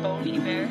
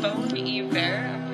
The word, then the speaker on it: Bon Iver: I think you mean Bon Iver. – Is it Bon Iver? Bon Iver? – Bon Iver. – Bon Iver.